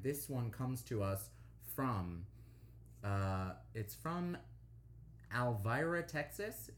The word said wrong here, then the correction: Alvord: Is that Alvord, Texas? Am I saying this right? – Yeah, it's North Texas town. Alvord, yeah, AF.